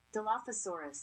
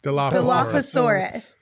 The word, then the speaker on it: Dilophosaurus: Philosophorus. – Dilophosaurus. – Dilophosaurus.